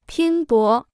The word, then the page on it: 拼搏: pīn bó